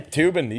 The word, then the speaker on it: Toobin: Yeah, Toobin. You got it. – For showing his hot raging cock on Zoom call. According to Vice magazine, the New Yorker has suspended reporter Jeffrey Toobin for masturbating on a Zoom video chat between members of the New Yorker and WNYC Radio last week.